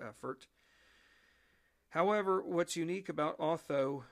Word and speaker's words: Otho: His name is the following: Colonel Otho, that's spelled O-T-H-O, Colonel Otho Holland Williams.